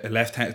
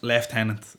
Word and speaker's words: lieutenant: I am just the difference between like a lieutenant, it's pronounced lieutenant. – Lieutenant in Ireland. Yeah. Lieutenant. – It's lieutenant.